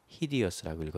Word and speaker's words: hideous: 그런데 읽을 때는 히디어스라고 읽어줘야 돼요.